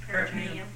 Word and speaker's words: peritoneum: It's not the peritoneum. Or how do you pronounce it? – Peritoneum.